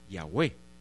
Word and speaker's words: Yahweh: Después lo pasamos como se pronunciaba esta forma, y sabemos que se pronuncia como Yahweh.